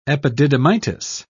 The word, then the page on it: epididymitis: British English